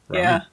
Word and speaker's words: Rami: Like, if you meet Rami and you can't pronounce my name, I'm going to tell you it's like raw meat without the T. Rami.